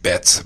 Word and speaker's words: Betts: It's actually pronounced Betts, B-E-T-T-S.